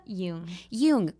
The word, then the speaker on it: Jung: Jung.